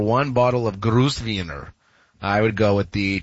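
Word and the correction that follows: Grüner Veltliner: And did I butcher the pronunciation of that Chardonnay, champagne, or whatever? Probably. – No, Gruner Veltliner. It's a white varietal usually found in Austria.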